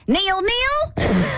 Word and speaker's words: nil-nil: We all know that ended in nil-nil. Or apparently it's, how do you say? – Nil-nil. – Nil-nil. – Yeah, not Neil. We're not calling a guy named Neil.